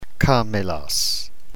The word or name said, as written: kamelos